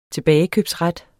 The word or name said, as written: tilbagekøbsret